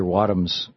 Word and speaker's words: Wadhams: We're talking with Professor Peter Wadhams.